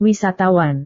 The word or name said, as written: wisatawan